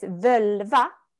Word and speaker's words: völva: It's so beautiful because it's völva, which is very close to vulva.